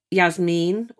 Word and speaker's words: Yasmine: You're saying it right when you say Yasmine, but the way I say it, it's so fast and run together, it sounds like Yasmine Akram.